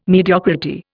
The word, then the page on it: mediocrity: Indian